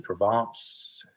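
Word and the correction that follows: Dravet: Oh, Travets.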